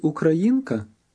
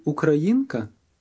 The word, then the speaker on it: українка: Ви українка? – Listen again. Note that the high point of the sentence is on the third syllable of the word Ukrainian. Then the tone drops back.